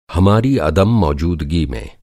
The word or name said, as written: अदम-मौजूदगी